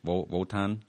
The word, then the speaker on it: Wotan: That's how you say it, right? Kind of like a V? Wotan? – You can. That's the way that a Scandinavian would say it.